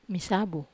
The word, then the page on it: masabu: Wamesa